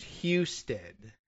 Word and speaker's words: Husted: Apparently, the local pronunciation is Husted. – Husted. Houston. – Yeah, right. So, like Houston, but without the O, but then every once in a while you run into a H-O-U that's pronounced Huston anyway.